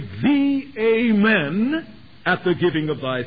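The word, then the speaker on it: Amen (אָמֵן): The word in the Old Testament, the Hebrew word, is to be pronounced Amen,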